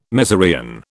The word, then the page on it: mezereon: British English